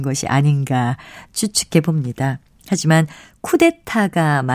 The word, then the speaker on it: 쿠데타: 흔히 쿠테타라고 하는 것은 쿠데타가 맞습니다.